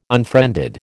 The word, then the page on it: unfriended: American English